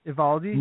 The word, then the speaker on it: Eovaldi: How many syllables? – Eovaldi. – Eovaldi? – Or Eovaldi. – Eovaldi? – Nate Eovaldi, yeah. – Nate Eovaldi, so the O is silent.